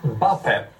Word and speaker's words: Mbappé: Det är den snabbaste spelaren i världen då, tror jag, Mbappé. Och det är nyttigt att ha, om man ska in bakom ytan, bakom deras huvudslinning. – Ja, Mbappé, rätt uttal.